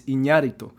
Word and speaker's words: Iñárritu: Fernando Moreno says, the Spanish pronunciation is Iñárritu.